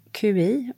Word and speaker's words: qi: Så QI och uttalat qi, qi är livsenergin.